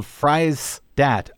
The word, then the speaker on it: Friesdat: Her last name is spelled Freistadt, like French fries, F-R-I-E-S. D-A-T, Lulu Freistadt.